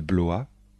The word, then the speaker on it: Blois: Les plus célèbres sont les châteaux de Chambord, de Blois, de Chenonceau et le château d'Amboise. Attention de bien prononcer les noms de châteaux. Répétez après moi.